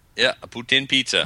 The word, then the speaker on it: poutine: No, but they've got, and I may say this wrong and sound perverted, is it poutine? – Poutine.